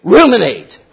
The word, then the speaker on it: ruminate: Sheep remunerate, is that it? Where they burp it up? Ruminate. I knew I was saying it wrong.